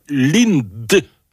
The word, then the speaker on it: LINTD: Et donc, ben voilà, cet artiste qui se fait appeler Lind, donc ça s'écrit L-I-N-T-D, et c'est déjà plus facile à dire que son...